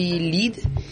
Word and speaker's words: lead: mas a gente chama de lead e boulder, é assim que a gente pronuncia.